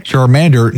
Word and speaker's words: Charmander: Charmander next to Jigglypuff.